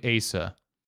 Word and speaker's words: Asa: It says A as in face, S as in sigh, and then A as in about. – So I would say that's It's Asa.